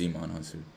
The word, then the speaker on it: Djimon Hounsou: it's Djimon Hounsou. Djimon Hounsou.